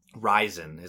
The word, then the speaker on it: Ryzen: They've been talking about it for a while, and it's been going under the code name Zen, Z-E-N. And just recently, they changed that to Ryzen. So R-Y-Z-E-N. Ryzen is another way I've heard it pronounced, so I don't know if I'm saying it right. – Ryzen, perfect.